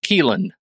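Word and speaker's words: Caoilfhionn: It's Welsh, and I'm pretty sure it's Welsh, CAOILFHION, Dunn, and it's pronounced Keelan. And that doesn't sound like Keelan to me at all.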